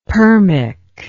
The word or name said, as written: permic